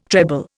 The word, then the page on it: treble: Indian